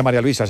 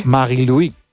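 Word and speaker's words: Marie-Louise: Bueno, no se dice María Luisa, se dice en su... – Sí. – Marie-Louis, Marie-Louis Etta, ¿no? – Así es.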